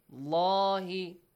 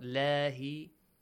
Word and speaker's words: الله: عند الله the word Allah, the lam in the word Allah is heavily pronounced. عند الله right? الله not لاهي Al-Islam.